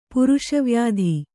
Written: ಪುರುಷವ್ಯಾಧಿ

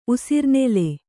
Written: ಉಸಿರ್ನೆಲೆ

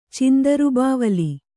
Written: ಚಂದ್ರಬಾವುಲಿ

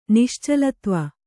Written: ನಿಶ್ಚಲತ್ವ

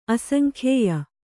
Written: ಅಸಂಖ್ಯೇಯ

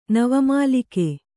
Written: ನವಮಲ್ಲಿಕೆ

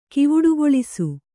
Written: ಕಿವುಡುಗೊಳಿಸು

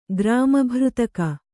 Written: ಗ್ರಾಮಭೃತಕ

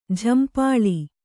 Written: ಝಂಪಾಳಿ

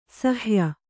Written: ಸಾಹಾಯ್ಯ

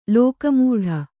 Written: ಲೋಕಮೂಢ

